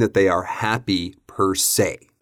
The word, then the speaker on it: per se: So the phrase we're talking about here is per se, P E R S E.